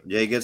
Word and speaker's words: Yeager: Yeager, según la pronunciación inglesa que nos ofrece uno de nuestros invitados, que es Sergio, colaboradores, que es Sergio.